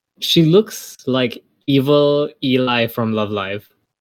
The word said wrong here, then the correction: Eli: Well, wouldn't it be Ellie, though? – Does it pronounce Ellie? Okay, it's Ellie, then.